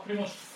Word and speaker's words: Primorska: Sen så har vi i väst då Primorska. Var det ett bra uttal? – Ja, Primorska.